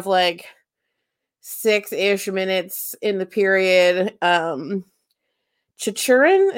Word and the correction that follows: Chychrun: Is that how we're pronouncing his last name? Uh, Chichurin? – Jakob Chychrun. – Chychrun. Thank you.